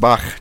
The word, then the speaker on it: Bach: You like how I pronounce Bach correctly, by the way, Levi? – Yeah, I do, dude.